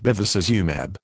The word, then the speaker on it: Bevacizumab: Bevacizumab, pronounced Bevacizumab.